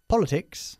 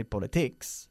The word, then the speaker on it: politics: Politics. – Política. – Good, we don't say politics, sino politics. – Politics. – Enfatizando la primera sílaba.